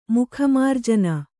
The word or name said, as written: ಮುಖಮಾರ್ಜನೆ